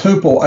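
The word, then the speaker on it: huipil: I feel like their clothing and their, it's, I think it's pronounced Hupil, H-U-L-P-I-L.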